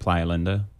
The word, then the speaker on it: Playa Linda: So, although in Titusville, we didn't say Playa Linda. We always said Playa Linda, you know, like Floridians. – Oh, is there a better pronunciation? Is there... – It's supposed to be Playa Linda.